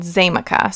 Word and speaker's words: Xaymaca: Before Columbus found the island, it was believed to be inhabited by a tribe of Indians that were referred to as Arawaks, A-R-A-W-A-K-S. And the island was actually called Zamaca, so it's spelled X-A-Y-M-A-C-A, which meant land of wood and water.